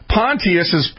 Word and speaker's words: Pontius: Now in the 15th year of the reign of Tiberius Caesar, Pontius, that's the proper way to say Pontius,